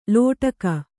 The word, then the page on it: ಲೋಟಕ: lōṭaka